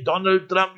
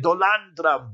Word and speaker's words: Donald Trump: You remember Modi, when you were praising Trump, you couldn't even say Donald Trump, you said Dolan Trump.